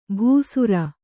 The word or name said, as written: ಭೂಸುರ